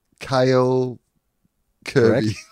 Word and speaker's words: Kale Kirby: What does it rhyme with? Okay. Gail Gerby. – Kale Kirby. – Correct.